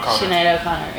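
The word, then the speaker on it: Sinéad O'Connor: I know it's Prince, this Prince song, but Sienna Connor, how do you say it in English? – Sinead O'Connor, yeah.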